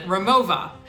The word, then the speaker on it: Rimowa: And by far, my favorite comes from a German company called Rimowa, or if you're actually German, you would pronounce it Rimowa.